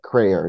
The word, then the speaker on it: Crayer: Yeah, it's Crayer, so rhymes with prayer. – All right. And I want to welcome into the program Dr. Nerissa Crayer.